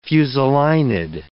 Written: fusulinid

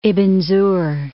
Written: Ibn Zuhr

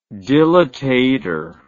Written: dilatator

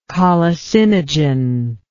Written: colicinogenic

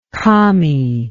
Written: commy

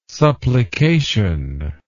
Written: supplication

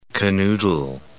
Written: canoodle

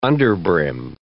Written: underbrim

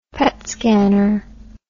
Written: PET scanner

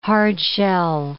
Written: hard-shell

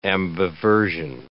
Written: ambiversion

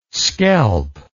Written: scelp

